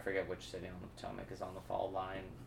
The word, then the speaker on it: Potomac: P-T-O-L. – Potomac. No. – Oh, Potomac. I always pronounced it Potomac. That's why I didn't know what you were talking about. – Oh. – I never heard it pronounced before.